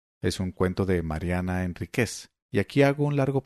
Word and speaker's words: Enríquez: Pronuncio Enríquez como palabra aguda y no como pronunciaríamos, que es Enríquez. Y es que en todos sus libros, y en donde he visto que ella escribe su nombre, Enríquez está sin tilde, con Z.